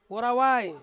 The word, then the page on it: worawai: Ansus